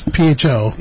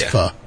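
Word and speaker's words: phở: It's not pho, it's pho. – Yeah, but they pronounce it pho. – Yeah, but I'm white and stupid, so it's pho. – Beef, meatball, bird. Pho. – Pho. – Is that what, pho. Yeah. So PHO is pho.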